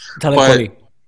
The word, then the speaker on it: Talib Kweli: Talib Kweli. Sorry if I'm mispronouncing that. – Talib Kweli.